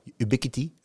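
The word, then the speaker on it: Ubiquiti: Eh, en, en, en, heb jij, kijk, als jij op elke verdieping een keurige ubiquity hebt hangen, ubiquity, nou, zo spreek je het bijna uit, dan, eh, dan, dan niks aan de hand.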